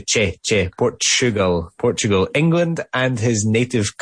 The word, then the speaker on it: Portugal: Very good, the way that pronounced Portugal there as well. We don't say Portugal, we say Portugal. – Siempre me acuerdo de que utilizar la che. Es Porchu, chu, chu, no tu.